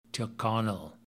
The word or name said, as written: tyrconnell